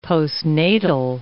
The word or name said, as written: postnatal